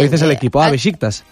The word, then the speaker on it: Beşiktaş: Pues tú lo has oído tú en algún sitio, porque, claro, luego de cómo se escribe a cómo se lee, pues es echarle imaginación. – Hombre, se escribe Beşiktaş. – Bueno, Beşiktaş. Bien,